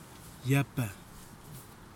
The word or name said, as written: yapha